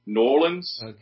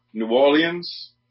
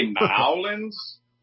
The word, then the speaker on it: New Orleans: Generally, it's going to be New Orleans, N-O-R-L-I-N-S, or New Orleans, which is closest to the original French, or New Orleans, almost like N-E-W with a little apostrophe O-R-L-I-N-S. Those are the three major pronunciations of the city's name. – Huh. – If you say New Orleans, we want to smack you immediately.